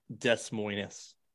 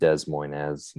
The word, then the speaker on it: Des Moines: Then you pronounce it wrong. It's Des Moines. – Oh, my bad. Des Moines.